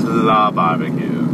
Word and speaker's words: La Barbecue: Okay, so Alley Barbecue's whack. – We call it La. – La. Maybe, oh. – Yeah, that's how they pronounce it. Like, it's La Barbecue.